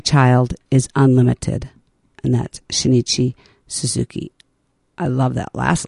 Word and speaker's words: Shinichi: Shinichi.